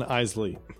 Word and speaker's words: Eisley: I hope I'm pronouncing this right, or Eisley, Mos Eisley, and Mos Espa, right? – Espa and Eisley.